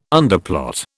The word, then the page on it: underplot: British English